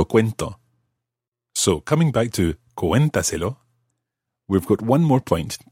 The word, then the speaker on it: cuéntaselo: There's an accent on the E of cuéntaselo. Because, of course, we need to keep the stress on that syllable, cuenta. We couldn't say cuéntaselo or cuéntaselo or anything like that. Cuéntaselo, it has to maintain the stress on the cué, cuéntaselo syllable. So we put a written accent.